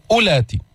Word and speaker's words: أولات: وإن كن أولات، أولات هذه الواو لا تلفظ، انظر عليها علامة، هذه الدائرة علامة على زيادة الحرف وعدم النطق به، فما بتقول أولات، ماذا تقول؟ أولات.